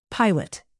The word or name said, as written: pilot